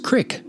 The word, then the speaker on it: Creek: If you live in central Illinois, that is pronounced crick, not creek.